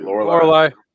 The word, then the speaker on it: Lorelai: Lorelai.